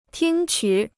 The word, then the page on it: 听取: tīng qǔ